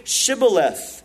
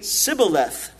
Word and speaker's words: Shibboleth: And if he said no, then they would say to him, "Then say Shibboleth." And he would say Sibboleth, for they could not pronounce it right. They couldn't pronounce that SH sound.